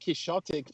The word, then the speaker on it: Quixotic: Exotic Quizotic? Is that how you pronounce that? – I'm unsure of that one myself. I call it Exotic Quixotic because of Don Quixote, but, you know.